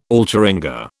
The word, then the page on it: alcheringa: British English